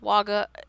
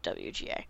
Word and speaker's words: WGA: and it is not pronounced WAGA, it is pronounced WGA.